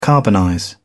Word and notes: carbonize: British English

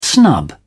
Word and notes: snub: British English

